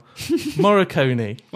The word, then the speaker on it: Morricone: Morricone.